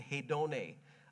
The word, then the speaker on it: hedone: The Greek word for desires there, if you transliterate it into the English language, it's hedone. We get the word hedonism from this word. You pronounce it hedone.